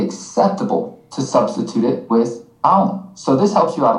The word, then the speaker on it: aún: And that's aún spelled with an accent mark above the U.